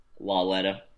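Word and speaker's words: Lauletta: On that note, fourth quarter, I believe, or second half even, Eli was replaced by Kyle Laletta. Is that how you pronounce his name? Laletta? – Yeah. – Laletta.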